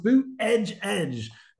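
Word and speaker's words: Buttigieg: And anytime he can do the Pete Boot Edge Edge, you pronounce it Boot Edge Edge.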